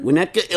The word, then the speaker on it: Winnetka: Okay, Keith Solomon from, how do you pronounce that, Winnetka? – Mm-hmm. – Winnetka,